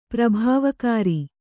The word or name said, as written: ಪ್ರಭಾವಕಾರಿ